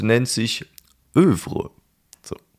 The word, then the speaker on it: Œuvre: Die Aussprache folgt dem französischen Œuvre und so weiter. Alternativ ist auch das etwas deutscher klingende Œuvre korrekt, beziehungsweise in unserer selbst eingedeutschten Lautschrift Œuvre mit Betonung auf der zweiten Silbe.